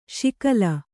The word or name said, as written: ಶಕಲಿ